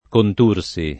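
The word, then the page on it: Contursi: italiano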